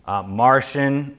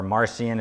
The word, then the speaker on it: Marcion: Martian, or Marcion as some pronounce it, early New Testament heretic, he believed the God of the Old Testament was different than the God of the New Testament.